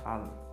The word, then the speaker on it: Alan: E aí eu respondo, Alan. Muita gente tem essa dúvida se é um L só, dois L's. Aí eu vou lá. How do you spell it? A-L-A-N. Alan. Então, meu nome, Alan. A-L-A-N.